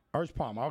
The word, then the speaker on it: Erspamer: Erspamer.